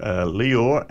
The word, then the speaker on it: Lior: There's another two Australian singers, too, Lior and Emma Louise. I think that's how you say his name, L-I-O-R, Lior and Emma Louise, with It's Only Natural.